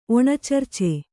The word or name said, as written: ಒಣಚರ್ಚೆ